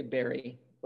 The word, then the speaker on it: bury: I think I say Barry. like a blueberry or raspberry, like bury the hatchet.